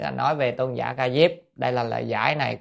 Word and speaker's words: Ca Diếp: Tôn giả Ca Diếp, chữ Diếp là Diệp vậy, nhưng mà chúng ta hay đọc là Ca Diếp nhé, mà đọc Diệp, Diệp là lá, cái lá cây gọi là Diệp.